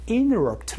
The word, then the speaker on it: interrupt: I'm sorry to interrupt 라고 말씀해 주시면 되고 여기에서 발음 포인트 하나 말씀드리면요 interrupt 라는 발음에서 interrupt 라는 발음으로 얘기를 하기도 합니다. NT에서 T를 날린 소리도 여러분이 들어볼 수가 있거든요.